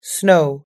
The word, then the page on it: snow: American English